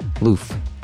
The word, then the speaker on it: Louf: I never know how to say it. Lou with an F. L-O-U-F. Louf. I'll say Louf.